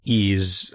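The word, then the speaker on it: Ys: And, like, on Ease, I think is how you say it. It's Y-S.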